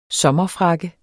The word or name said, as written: sommerfrakke